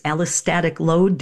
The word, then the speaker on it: allostatic load: And you taught me that new phrase, allostatic load.